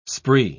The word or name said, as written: spree